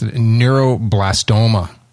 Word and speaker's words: neuroblastoma: He's dying of cancer, neuroblastoma. What is it? – I think you got it. Said it right the first time. – Diagnosed with neuroblastoma.